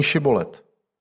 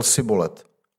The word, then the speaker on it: Šibolet: Jestliže odpověděl nejsem, vyzvali ho, tak řekni Šibolet. On však řekl Sibolet – a nedokázal to přesně vyslovit.